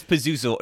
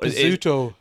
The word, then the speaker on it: Pazuzu: How do you say It's Pazuzu. – Pazuzu.